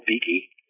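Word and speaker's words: Beeke: And I don't know him personally, but Joel Beakey, if I'm pronouncing his last name correctly.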